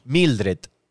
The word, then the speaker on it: Mildred: Mildred.